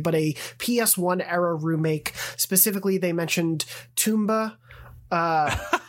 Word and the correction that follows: Tomba: It's, it's I think it's Tomba. – Is it Tomba? I, yeah. And I said Tumba.